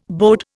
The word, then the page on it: bode: Indian